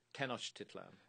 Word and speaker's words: Tenochtitlan: Tenochtitlan.